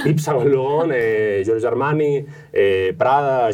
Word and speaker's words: Yves Saint Laurent: Eh, eh, Yves Saint Laurent, ¿lo he dicho bien? – Muy bien.